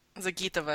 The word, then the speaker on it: Zagitova: If you go to, correct me if it's pronounced incorrectly, Alina Zagatova's page. Zagitova, I think.